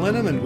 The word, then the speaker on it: Linneman: Val Linneman, which rhymes with cinnamon,